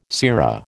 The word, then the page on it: sera: American English